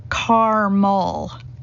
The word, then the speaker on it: caramel: Said with two syllables here, caramel.